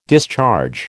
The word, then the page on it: discharge: American English